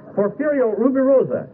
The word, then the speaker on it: Porfirio Rubirosa: There's a Porfiria Rubirosa. I can never pronounce. I knew I'd have trouble. You know, this is the problem. You buy cheap popcorn, what are you going to do? Porfirio Rubirosa. Yeah, there's one of these Rubirosa dolls.